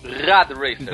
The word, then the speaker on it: Rad Racer: Olha, e, e, só que ele foi baseado num outro jogo de NES chamado Red Racer, pronunciei certo? – Rad Racer. Exatamente.